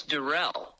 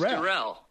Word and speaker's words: Durrell: It's Durrell. – Durrell. – Just Durrell. You were trying to make it a little French. It's just Durrell.